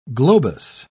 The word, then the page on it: globous: British English